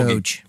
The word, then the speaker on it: Doge: Eh, Dogecoin, jag tror det var Doge, men det måste ju vara Dog då, som det är en hund. – Doge. Doge. Doge. Det är Doge.